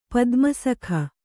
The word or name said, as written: ಪದ್ಮಸಖ